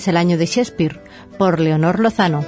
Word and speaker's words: Shakespeare: Con permiso del Mango de Lepanto, que celebra la misma efeméride, 2016 es también el año de Shakespeare. – William Shakespeare, los entendidos pronuncian Shakespeare, nació en Stratford-upon-Avon, una pequeña población situada en el condado de Warwickshire, a unos 160 kilómetros al norte de Londres.